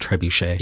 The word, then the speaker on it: trebuchet: Also, I know it's pronounced trebuchet. – Yes.